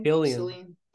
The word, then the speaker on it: Cillian: Cillian.